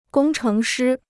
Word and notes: 工程师: gōng chéng shī

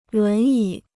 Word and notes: 轮椅: lún yǐ

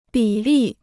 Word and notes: 比例: bǐ lì

